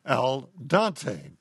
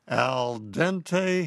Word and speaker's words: al dente: Every time he pronounces it El Dante.